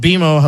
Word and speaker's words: BMO: It's pronounced BMO.